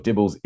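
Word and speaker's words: DIBELS: Well, I'd say Dibbles because that's how they pronounce it.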